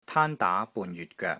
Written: TAAN1 DAA2 BUN3 YUT6 GOEK3

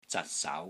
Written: ZAT6 SAU2

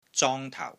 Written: ZONG1 TAU4

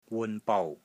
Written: WUN6 BOU6

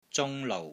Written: ZUNG1 LOU6

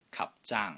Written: KAP1 ZAANG1

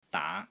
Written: DAA2

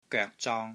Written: GOEK3 ZONG1

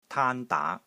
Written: TAAN1 DAA2

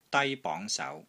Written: DAI1 BONG2 SAU2

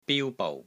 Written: BIU1 BOU6

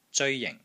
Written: ZEOI1 JING4

